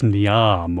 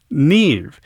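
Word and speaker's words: Niamh: Next on Classical 89, a piece that is spelled N-I-M-H. You might think it's Niam or something like that. It's actually pronounced Neve. It's an Irish title.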